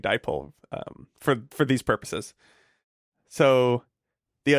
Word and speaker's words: dipole: So here's the thing about dipole. It has two syllables, di and pole.